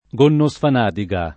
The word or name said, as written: Gonnosfanadiga